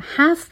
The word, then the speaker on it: half: Within 5 years توی 5 سال over half of the nation over به معنای بیش از هستش یا more than و half به معنای نیم یا نصف هستش. L توی این کلمه سایلنته و تلفظ نمیشه. Over half of the nation.